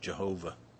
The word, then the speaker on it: Jehovah: A second name that we find in Genesis is the word YHWH, or sometimes pronounced Yahweh, or sometimes pronounced Jehovah.